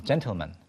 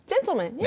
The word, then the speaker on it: gentleman: 이게 신사를 가리키는 gentleman이라고 하지 않는 것처럼 gentleman. – gentleman.